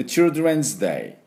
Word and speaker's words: Children's Day: で、ですからこのまた DWC ですか、 children で children's day、この D と R をダブルで言わなきゃいけないですね、これがポイントです。